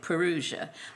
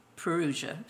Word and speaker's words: parousia: Now that's the way Jack pronounced it, so I pronounce it that way, parousia. I've heard other ministers call it parousia, but Jack always said parousia, so I follow him.